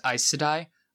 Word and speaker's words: Aes Sedai: So in the pronunciation guide, it is Aes Sedai.